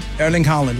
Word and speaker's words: Erling Haaland: Erling Haaland, yes.